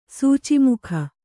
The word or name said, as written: ಸೂಚೀಮುಖ